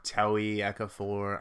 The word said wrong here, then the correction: Ejiofor: I don't know how to say his name. – Chiwetel Ejiofor.